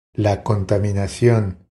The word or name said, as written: La contaminacion